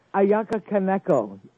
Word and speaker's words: Ayaka Kaneko: The other is Maya Kanaka. Is that how you pronounce her last name? – Ayaka Kaneko.